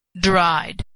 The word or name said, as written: dried